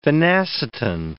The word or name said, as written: phenacetin